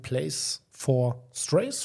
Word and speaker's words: Place for Strays: Und zwar geht es um den Verein Place for Strays, spricht man das richtig so aus? Ja, ja also mhm.